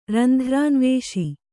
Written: ರಂಧ್ರಾನ್ವೇಷಿ

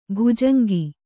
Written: ಬೊಜಂಗ

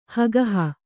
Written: ಹಗೆಹು

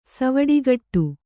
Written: ಸಿವುಡುಕಟ್ಟು

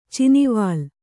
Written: ಚಿನಿವಾಲ್

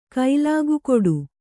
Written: ಕೈಲಾಗುಗೊಡು

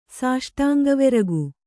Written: ಸಾಷ್ಟಾಂಗವೆರಗು

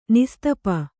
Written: ನಿಸ್ತಪ

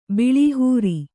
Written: ಬಳಹರಿ

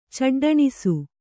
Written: ಚಡ್ಡಣಿಸು

